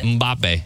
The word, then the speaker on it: Mbappe: Mbappe.